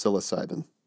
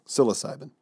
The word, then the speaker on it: psilocybin: Oh, and speaking of, I think last episode I might have mispronounced psilocybin as psilocybin or something. Psilocybin, I think is how it's pronounced. Psilocybin, right? – No, God. Psilocybin.